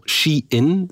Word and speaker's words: Shein: Maar bon, Shein, zo zeg je het. Ja. – Het komt van She Inside. She Inside, voilà.